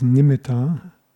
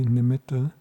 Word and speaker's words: nimitta: Nowadays when people talk about a nimitta, often they pronounce it as nimitta, but the Pali has a double T, nimitta.